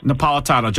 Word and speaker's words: Napolitano: Judge Neapolitano, I think that's the way he pronounced it, from Fox News. – Napolitano, Judge Napolitano.